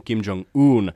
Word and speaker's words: Kim Jong-un: Äh, sanoin äh, Kimin nimen Kim Jong-un, joka on siis aivan väärin. Oikea ääntämys on Kim Jong-un en tiedä, mistä moinen niin kuin ääntämysvirhe on saanut alkunsa. Varmaan siitä, että mä oon ajatellut ranskan kielen sanaa yksi, eli ään, joka laustaan ään kirjoitetaan un.